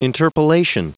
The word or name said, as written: interpolation